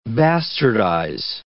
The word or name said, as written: bastardize